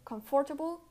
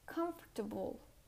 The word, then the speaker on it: comfortable: And although this word has often four syllables, it's only pronounced like three syllables. It's pronounced comfortable, comfortable. The second O is not pronounced. You don't say comfortable, you say comfortable comfortable with the er, er, comfortable.